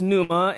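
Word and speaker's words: pneuma (πνεῦμα): But it would be pronounced pneuma, and you've heard me share this word many times.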